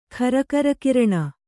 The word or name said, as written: ಖರಕರಕಿರಣ